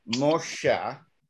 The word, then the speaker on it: Moshe: See, the name Moses, if you take the vowels out of it and you use the Hebrew pronunciation, it is Moshe, an M sound, an SH sound, and an H at the end.